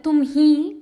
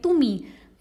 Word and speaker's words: tumi: But you're right, we do not stress on the h sound, that tumhi, that sound doesn't come. It's more like tumi.